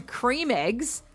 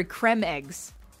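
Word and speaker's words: Creme Egg: Because Cadbury caused an absolute panic yesterday when they released a statement saying that Cadbury cream eggs are actually supposed to be pronounced Cadbury creme eggs.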